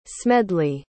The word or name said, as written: smedley